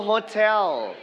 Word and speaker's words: hotel: so we are going to stress on the second syllable คำนี้เราจะเน้นเสียงหนักไปที่พยางค์ที่2ก็คือคำว่า tell okay what about some hotel very good so hotel it's a place where you pay to have a room to sleep in to stay andTo eat meals.